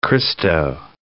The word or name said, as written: Christo